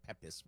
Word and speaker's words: Pepys: So Pepys, and it's spelled P-E-P-Y-S, if you want to read the diaries. Jack says they're brilliant. It looks like Pepys, but it's Pepys.